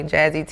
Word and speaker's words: Jahze: Jahze TV, the H is silent in my head.